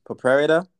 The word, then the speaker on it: proprietor: A pro prayer, proprietor. – Proprietor. Yep. – Proprietor. Yeah, definitely.